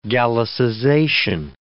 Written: gallicization

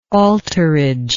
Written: altarage

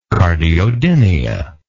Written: cardiodynia

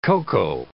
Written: cocoa

